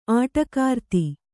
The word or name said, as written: ಆಟಕಾರ್ತಿ